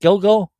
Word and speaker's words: Gilgo: Is that how you pronounce it, Gilgo? – Correct.